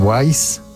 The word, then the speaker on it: Weiss: Aunque bueno, se supone en el Bandcamp de Dan Wise, que se escribe D-A-N, Dan Wise, que es W-E-I-S-S, lo pueden encontrar tanto en CD como en vinilo a un precio bastante razonable.